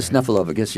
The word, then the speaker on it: Snuffleupagus: You were the original puppeteer behind the character Snuffleupagus. That's, I don't know if I'm pronouncing that right. – Snuffleupagus, yeah.